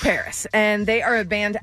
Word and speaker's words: PVRIS: I picked a band that I've liked for a while, but this song is brand new by Paris. You might know them. – Paris Hilton? – By the way, they spell it P-V-R-I-S, so people might think, oh, maybe it's Pavrus. It's not.